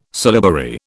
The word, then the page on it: syllabary: British English